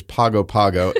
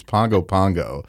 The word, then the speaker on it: Pago Pago: Tutuila is the largest island, and it has the capital on that island, which we have called for the last 10 years Pago Pago, which is an incorrect pronunciation. It's Pongo Pongo. – That's right. – It rhymes with song.